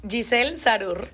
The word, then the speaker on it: Giselle Zarur: Giselle Zarur.